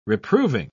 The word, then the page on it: reproving: British English